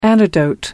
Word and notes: antidote: American English

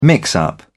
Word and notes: mix-up: British English

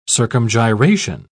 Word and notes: circumgyration: British English

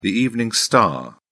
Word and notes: evening star: British English

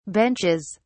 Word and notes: benches: British English

